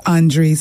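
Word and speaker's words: Andree: He says, my name is pronounced just as Andre sitting next to you.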